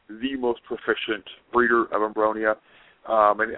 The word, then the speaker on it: Abronia: Abroni? Is that how you say it? – Abronia.